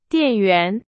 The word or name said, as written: 电源